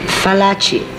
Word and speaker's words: Fallaci: Fallaci, F like flower. Not V like Venice.